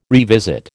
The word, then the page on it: revisit: American English